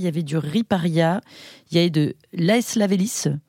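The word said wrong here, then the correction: aestivalis: Estivalis. – Voilà, Estivalis, je le prononce, et je crois qu'il y avait un peu de la brusca aussi, on avait 4 vitis.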